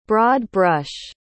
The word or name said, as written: broad-brush